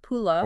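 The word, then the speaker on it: pulla: They literally say, if you miss eating pula, that's P-U-L-L-A, pronounced pula.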